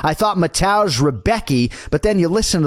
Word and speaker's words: Mateusz: I'm Mateusz Rembetski.